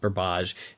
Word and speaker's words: Burbage: By the way, I know I alternate between Burbage and Burbage. I'm so sorry. I think it's a Ben thing. I've heard the name pronounced Burbage. And once you hear that really elegant pronunciation, it never leaves you, I promise.